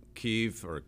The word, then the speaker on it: Kiev: And President Zelensky in Kiev, that's pronounced both ways,